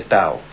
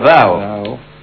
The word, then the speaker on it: Tao: Por ejemplo, Tao, en realidad no se dice Tao, sino Dao.